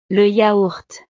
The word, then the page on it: le yaourt: frances